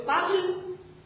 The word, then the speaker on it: parle: Значит, в форме «я» добавляется буква «э», которая не читается. Соответственно, «я говорю по-французски», будет. – Je parle français.